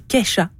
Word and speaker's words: Kesha: Et c'est donc la star américaine Kesha qui est en vedette. – Oui, et on dit Kesha.